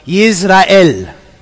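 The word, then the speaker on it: Yisrael (ישראל): Jezreel is something like Yisrael. Does it sound like any other word, you know? Israel is Yisrael.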